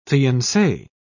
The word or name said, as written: fiance